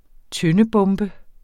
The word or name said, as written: tøndebombe